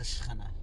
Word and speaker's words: Shekhinah: You're not as close to the Shekhinah.